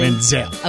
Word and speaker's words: Manziel: Manziel.